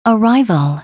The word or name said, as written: arrival